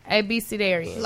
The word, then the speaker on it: Abecedarian: Aspidarian, is that what it... Aspidarian... – Abecedarian. Like, no, that's really how it's pronounced.